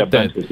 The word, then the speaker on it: Bencic: Am I saying that right? – Yeah, Bencic, yep.